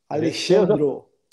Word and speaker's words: Alexandro: E uma prova que não, que não tem nada combinado, é que eu até pronunciei seu nome errado, então você me desculpa. É, Imagina. – É, é Alexandro, certo? É, Alexandro. – Alexandro.